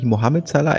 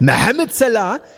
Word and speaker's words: Mohamed Salah: Und nur ich kann ihn ja richtig aussprechen. Er heißt natürlich nicht Mohamed Salah, er heißt Mohamed Salah.